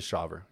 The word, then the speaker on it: Shawver: Are we going with Shawver? Is that how we're going to pronounce it? – What is it? thought it was Shauver. – Shauver. Yeah. Yes, it is Shauver.